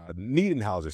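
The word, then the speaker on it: Niederhäuser: Same with Yannick Neudenhauser. Niedenhauser. Sorry, I want to say his name correctly.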